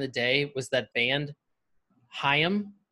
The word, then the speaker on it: Haim: But I said it was pronounced Haim like time. Here's why. Look, I looked it up on the internet. There was, like, Rolling Stone was like, it's like Haim, like it rhymes with time.